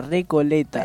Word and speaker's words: Recoleta: Jugó contra Recoleta. – Perfecto. – ¿Lo dije bien? – Sí, que yo estoy bien. – Sordo. – No, lo dijiste bien, sí.